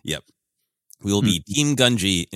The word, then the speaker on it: Gungi: His name is pronounced 18 different ways in the arc of the Clone Wars that introduces him us to him. I gravitated and stuck to Gungi for some reason. But since this episode is about respect, he roared his name, and it was translated by the Bad Batch as Gungi. I'm going to assume that that is the correct pronunciation and be respectful of Gungi.